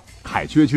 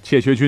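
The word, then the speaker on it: 怯薛: 话说后头呢，还有两支劲旅啊，一支是一代天骄成吉思汗亲手组建的禁卫军，叫凯缺军，哎，也可以读成切缺军；还有一支呢，就是明末真正的精锐啊，可以决定当时历史走向的夷丁突骑啊，也就是我们常听到的吴三桂手下的关宁铁骑。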